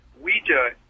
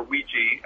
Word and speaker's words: Ouija: Well, you know, originally, the first talking boards, and this is the difference, Ouija, or Ouija, as it's sometimes pronounced, is actually a trademark.